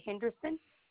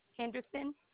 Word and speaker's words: Henderson: Okay, Shawanda Henderson. – Can you spell that for me? – Shawanda, S-H-A-W-A-N-D-A. Henderson, H-E-N-D-E-R-S-O-N.